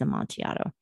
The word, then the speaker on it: Amontillado: So we have no way of knowing how he would have pronounced it, but it is Amontillado.